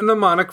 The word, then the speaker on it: mnemonic: There is even a mnemonic for spelling the word mnemonic, because it has a tricky silent letter in the word.